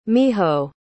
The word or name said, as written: miho